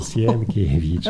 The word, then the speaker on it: Sienkiewicz: And they pronounce with three words, Siena, Chiesa, Vicino, Sienkiewicz.